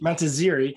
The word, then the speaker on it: Montazeri: Today is all about digital signage, and joining me today to talk about digital signage is Michelle Montazzari. Is that, did I pronounce that right? Montazzari? – Close, Montazzari. – Montazzari, yes.